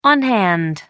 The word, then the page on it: on hand: American English